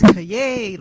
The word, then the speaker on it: Callejas: I always say it's Callejas, like you're celebrating.